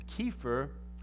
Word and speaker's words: kefir: But my wife, as most of you know, is the kefir queen. Kefir is how we learn the Russians pronounce it.